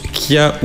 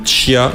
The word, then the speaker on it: chia: Ça s'écrit chia, mais ça se prononce kia ou chia. Ça dépend. Il y a deux variantes possibles. Chia, kia.